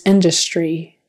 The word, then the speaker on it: industry: When words are spelled with a TR in English, we sometimes say them with a CHR sound, like tree, tree. So industry, and make sure that vowel at the end is an E, that it's spelled with a Y, but you say an E, long E.